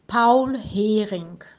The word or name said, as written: Paul Hering